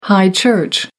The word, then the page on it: high church: American English